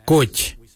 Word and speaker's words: goodj: Karli is spelled G-I-I-L-Y-I, karli. For the traditional stone axe, we say koch, koch. Koch is spelled G-O-O-D-J, koch.